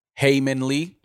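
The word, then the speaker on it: Hae Min Lee: After 22 years of being convicted of killing Heyman Lee, I believe is her name.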